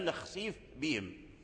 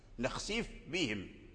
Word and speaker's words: نخسف بهم: أظهر الفاء عند الباء في قوله تعالى إن شاء نخسف بهم، نخسف بهم، نخسف بهم، إذن لابد من بيان الفاء، لابد من بيان الفاء ثم عاد تنطق بالباء، نخسف بهم، والفاء خفية والباء شديدة، والباء هي أقوى من الفاء، وإذا اجتمع حرف ضعيف مع حرف قوي فلا بد من إظهار الحرف الضعيف عند الحرف القوي حتى